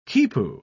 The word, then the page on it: quipu: British English